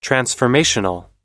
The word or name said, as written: transformational